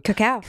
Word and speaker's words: cacao: Cacao, yeah. – Am I saying that right? – Cacao.